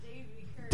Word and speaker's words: Devi Kirsch: It's Devi Kirsch.